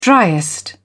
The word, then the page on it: driest: British English